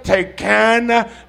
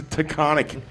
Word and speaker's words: Taconic: There was a bunch of signs, and me and Colette are both there trying to pronounce it. Like, what is that? Tecana? We're like, oh, and like after like half an hour, like it's a Taconic.